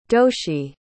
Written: doshie

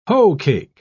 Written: hoecake

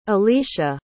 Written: elicia

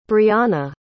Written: breonna

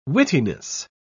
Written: wittiness

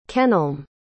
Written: kenelm

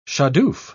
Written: shadoof

